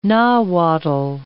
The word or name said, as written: Nahuatl